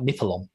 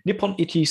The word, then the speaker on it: Nippon Ichi: So, Disgaea is originally a game created by Nippon Ichi Software. I hope I'm saying that right. I keep saying like Nippalon. Nippon Ichi Software, which is also abbreviated to NIS to make it easier for people like me to speak it.